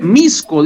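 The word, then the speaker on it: Mixco: Ahora pronuncia hasta bien los nombres. Misco, dice Freddy Rodríguez. – Cuidadito, les escucho la X en el nombre de ese equipo. Misco.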